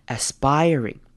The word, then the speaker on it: aspiring: Aspiring 这个字跟 aspirations 它是同样的字根，但是呢，因为这边字的组成不太一样，所以我们这边的 IR 反而会念成长母音 I 的声音。 Aspiring, aspiring.